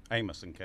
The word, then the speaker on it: Amason: Amason, Caleb. – Amason. Okay, just, I was putting the emphasis on the wrong syllable there.